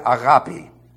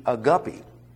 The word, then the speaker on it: agape: Remember years ago, a man came to me and he said, you know, you're not pronouncing that correctly. I said, I beg your pardon. He said that it should be a guppy.